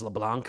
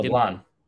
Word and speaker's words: LeBlanc: Yeah, shout out to uh, was it Charles LeBlanc for getting picked off at second? LeBlanc. No, no C. Silent C.